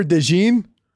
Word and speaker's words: DeJean: Dude, Cooper DeJean? Is that how you say it? – Yeah, that's how you say dude.